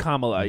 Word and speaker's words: Kamala: Pronounced Kamala, yes.